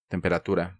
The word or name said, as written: Temperatura